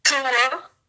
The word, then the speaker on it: Tua: Tua.